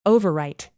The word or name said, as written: overwrite